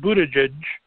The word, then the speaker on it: Buttigieg: If you've not heard of Pete Buttigieg, don't know how to pronounce it correctly, Pete Buttigieg, he is the mayor of South Bend, Indiana, possibly running for president.